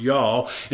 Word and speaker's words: Jah: It's spelt J-A-H, but it's pronounced YAH.